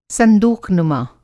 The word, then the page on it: संदूक़नुमा: Hindi